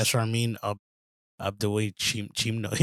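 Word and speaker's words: Sharmeen Obaid-Chinoy: But, how do you say her name? Shermin Obadachinoy? Obadachinoy. – I think you're right. Yeah, Shermin Obadachinoy. Yeah, I think you're saying it right.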